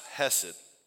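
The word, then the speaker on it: hesed (חֶסֶד): In the Old Testament, there's a Hebrew word that's pronounced hesed.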